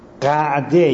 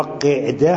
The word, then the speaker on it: قعده: بله ذی‌قعده، ذی‌الحجه، البته اینا تلفظ درستش قعده یا قعده هر دوش درسته،